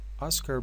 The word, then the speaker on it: Oscar: در رابطه با جایزه اسکار که از کلمه اسکار استفاده میشه ولی تلفظش هست Oscar Best Award یا Oscar Best Actor یعنی بهترین بازیگر اسکار.